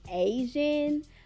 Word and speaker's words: Asiahn: Her name is Asian, but it's spelled A-S-I-A-H-N, and it had five songs on there.